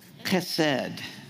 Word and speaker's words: chesed: In the Old Testament, there's this word, H-E-S-E-D. But it's pronounced like it has a C at the front of the H. Chesed. Chesed.